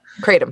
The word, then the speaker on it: kratom: a leaf of faith on Netflix with the I think you pronounce it kratom I've heard it oh, pronounced. Oh, Kratom. – Yeah. Kratom. Uh, yeah, it's Kratom.